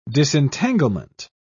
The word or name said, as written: disentanglement